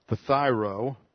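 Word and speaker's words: phthyro (φθείρω): The Greek word here is phthyro. A little difficult for us in English to pronounce this diphthong. Looks like this in the Greek. P-H-T-H. Put the first person ending there. P-H-T-H-E-I-R-O. Phthyro.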